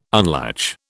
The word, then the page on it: unlatch: British English